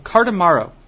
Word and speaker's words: Cardamaro: I also picked up a beverage that is called Cardamaro.